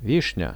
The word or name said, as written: vishnia